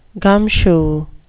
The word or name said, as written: gumshoe